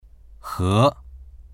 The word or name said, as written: he2